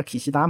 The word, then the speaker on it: 岸田: 可是，在日语里面，岸田叫 Kishida 嘛，可是我们不会说叫 Kishida 首相，我们说叫岸田首相。